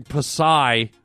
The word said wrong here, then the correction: Psy: She wrote in and said it's pronounced Psy, S I. I had it as P S Y, Psy, silent P, silent S, silent Y, if that's possible. Psy is pronounced S-I, Psy.